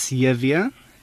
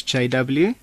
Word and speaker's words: JW: In Afrikaans is Yevia, and in English is JW, and Jo.